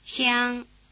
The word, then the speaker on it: xiang: The tongue is held in a tight E position. Listen. – Xiang, xiang, xiang. – Try repeating it, spreading your lips wide in a tight grin as you start the syllable.